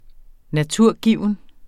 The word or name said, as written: naturgiven